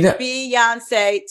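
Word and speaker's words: Beyoncé: Taria on his lips, Beyonce has three syllables. You need. – Beyonce, Taria.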